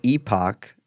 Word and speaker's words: epoch: And just so everyone knows, it's pronounced epoch. – Epoch? – No. Correct. I'm correct. Thank you, Diverter. It's epoch, and we'll link to the votes.